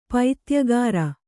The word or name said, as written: ಪೈತ್ಯಗಾರ